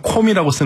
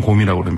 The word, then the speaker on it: 곰: 우리 보통 신문에서 콤이라고 쓰는데 현지 발음은 곰이라고 합니다.